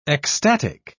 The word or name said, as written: ecstatic